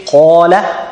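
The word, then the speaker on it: qala (قال): So when you say qala, qala, qala, qala, it's the heaviest qaf you have ever pronounced, because it has fatha, and it's not only has fatha, but it's followed by the long alif.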